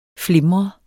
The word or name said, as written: flimre